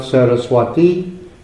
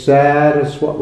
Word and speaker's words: Sarasvata: And the word is not Saraswati, it's Saraswata with a long A, Saraswata. And then it's put into the vocative case, so the final A is changed to English E.